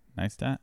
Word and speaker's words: Neistat: Did I say his name right? Nice stat. – I think so.